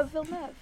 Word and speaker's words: Villeneuve: That's how you say his name. Ah. I thought it was Denis Villeneuve. – Oh, Villeneuve.